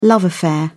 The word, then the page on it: love affair: British English